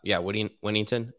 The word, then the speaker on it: Whittington: Like, Jordan Whittingham gets – or, Whittington, that's how you say it, actually, is involved in the pass game as well.